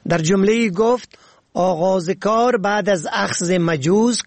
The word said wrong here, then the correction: مجوز: کاری ممکن است، در حالی که این کلمه مجوز است نه مجوز. مجوز سند اجازه و اسناد مربوط به آغاز کار است.